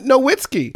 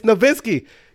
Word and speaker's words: Nowitzki: And even beyond then, still continue to call him Nowitzki. We know that's not how you say it. Like, how did you have... He's been around since 98. It's Nowitzki. Get it right.